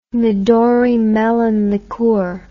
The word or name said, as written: midori melon liqueur